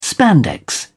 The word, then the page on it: spandex: British English